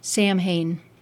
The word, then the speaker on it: Samhain: Incidentally, Samhain is spelled S-A-M-H-A-I-N, as though it ought to have been pronounced Samhain, but its Celtic pronunciation is Samhain.